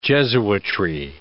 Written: jesuitry

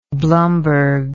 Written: Blumberg

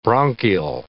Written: bronchial

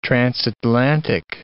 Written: transatlantic

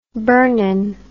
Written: burnin

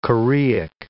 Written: choreic

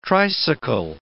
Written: tricycle

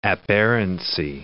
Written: aberrancy

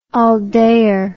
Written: all dayer

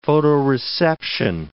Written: photoreception

